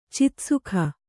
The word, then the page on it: ಚಿತ್ಸುಖ: citsukha